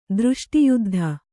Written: ದೃಷ್ಟಿಯುದ್ಧ

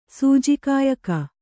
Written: ಸೂಜಿಕಾಯಕ

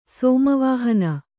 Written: ಸೋಮವಾಹನ

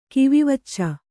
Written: ಕಿವಿವಚ್ಚು

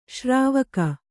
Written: ಶ್ರಾವಿಕೆ

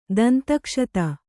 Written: ದಂತಕ್ಷತ

